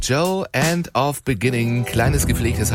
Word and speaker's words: Djo: Joe nennt er sich. Und es ist Joe Keery, spielt in Stranger Things. – Ja, und Joe schreibt man D-J-O.